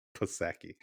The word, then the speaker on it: Psaki: Psaki.